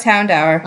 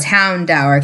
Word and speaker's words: Houndour: Because dour means, like, dark and sad and depressing. So it's Houndour because it's dark type.